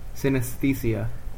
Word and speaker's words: synesthesia: Synesthesia? I can't pronounce this. Can you pronounce this? her and her brother both have this disease. – Synesthesia.